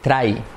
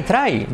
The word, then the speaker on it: try: Decir try. Try, try. You have to try. No. Recuerda que la R es try.